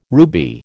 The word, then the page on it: ruby: American English